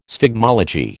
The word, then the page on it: sphygmology: American English